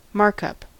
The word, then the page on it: markup: American English